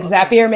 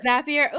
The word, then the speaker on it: Zapier: And so is that how you say it, Zapier? – Yeah, like Zapier makes you happier. – Zapier.